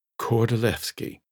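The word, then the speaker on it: Kordylewski: It looks like Lewski, but it's Levski because the Poles pronounce a W like a V. Kordolewski.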